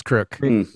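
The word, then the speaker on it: Kreuk: Speaking of casting, Kristen Kriuk, is that how you say it? – Kriuk, it's Kriuk.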